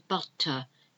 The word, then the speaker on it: butter: So we've got butter, and if you can hear the difference, I'm not pronouncing the R at the end, butter. It's almost like an A, butter, not butter, butter.